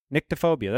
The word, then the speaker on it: nyctophobia: It's N-Y-C-T-O. Nyctophobia. That's how you pronounce it.